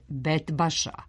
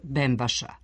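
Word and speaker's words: Bentbaša: U pesmi se pominje poznati sarajevski lokalitet Betbaša, a u narodu se ustalio izgovor Bembaša. Koren ove reči bent znači vodena brana.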